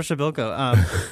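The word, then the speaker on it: Przybyłko: Now we're finally saying his name correctly. – Yeah, Kasper Schabalko.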